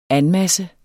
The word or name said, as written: anmasse